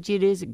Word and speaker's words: geodesic: Well, we knew about the Geodesic Domes. G.O.G. Sick Jones sounds stupider than Geodesic Domes. – It's pronounced geodesic. – Okay. – Like desk.